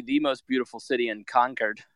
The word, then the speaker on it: Concord: Concord. – I was informed at the podcast taping today that is how you say it. It is not Concord. a true Englishman should say Concord.